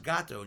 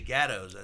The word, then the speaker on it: Gatto: I go, it's pronounced Gatto, and you guys say Gattos. I said, as long as I've been getting pizza from there, which has been a long time, It's they call it Gatto.